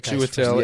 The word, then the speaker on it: Chiwetel: Okay, so the rest of the names are August Diehl, Andre Brower, Daniel Chiwetel Ejiofor. How do you pronounce that? – Chiwetel.